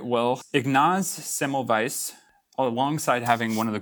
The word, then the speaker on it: Semmelweis: We'll be talking about a guy named Ignaz Semmelweis, and I hope I'm pronouncing that right.